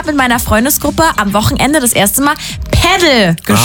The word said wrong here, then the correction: Padel: Ich meine, Padel. Es ist wohl Spanisch. Man soll es Padel aussprechen.